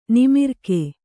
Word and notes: ನಿಮಿರ್ಕೆ: nimirke; kannada

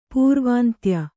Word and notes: ಪೂರ್ವಾಂತ್ಯ: pūrvāntya; kannada

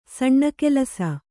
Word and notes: ಸುಣ್ಣಗೆಲಸ: suṇṇagelasa

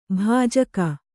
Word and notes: ಬಿಜಿಕ್: bijik

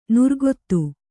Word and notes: ನುರ್ಗೊತ್ತು: nurgottu

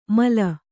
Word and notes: ಮಾಲು: Kannada

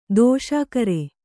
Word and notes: ದಿಶಾಕರಿ: Kannada

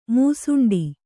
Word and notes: ಮೂಸುಂಡಿ: Kannada

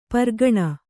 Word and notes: ಪೀರಖಾನಿ: Kannada

